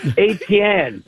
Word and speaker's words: Etienne: Listen, it's ATN.